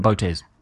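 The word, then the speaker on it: Botes: I don't want to pronounce his name wrong. – Botez. – Costa Botez, thank you, has received another honor.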